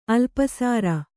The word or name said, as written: ಅಲ್ಪಸರ